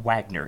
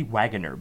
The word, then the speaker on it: Waggoner: Um, it's not Wagner, it's actually Waggoner because it has two G's and then an O N, so it's spelled like wagon.